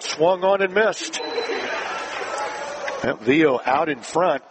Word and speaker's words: Viau: Spelled V-I-A-U. Sophomore second baseman. Pronounced Vio.